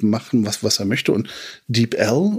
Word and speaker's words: DeepL: Also Wikipedia sagt, es wird DeepL ausgesprochen.